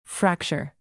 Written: fracture

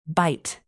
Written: bite

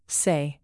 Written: say

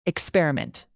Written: experiment

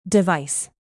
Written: device

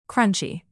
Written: crunchy

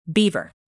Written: beaver

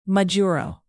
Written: majuro

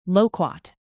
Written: loquat

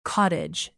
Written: cottage

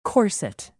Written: corset

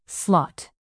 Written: slot

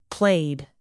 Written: played

